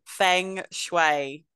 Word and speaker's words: feng shui: How did you say that? Feng shui. – Feng shui.